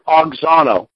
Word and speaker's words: auxano: That word increased is the Greek word auxano, transliterated A-U-X-A-N, long O.